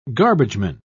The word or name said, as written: garbageman